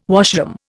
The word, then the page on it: washroom: Indian